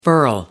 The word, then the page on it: furl: American English